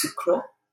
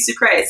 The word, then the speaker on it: Sucre: Oh, my name as a burlesque performer is Sophie Sucre. – Yes. – Pronounced Sucre, which I believe is the French pronunciation.